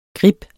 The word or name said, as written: grib